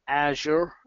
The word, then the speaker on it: Azure: You've got Office 365, and you've got Azure, or Azure, as Microsoft say we should pronounce it.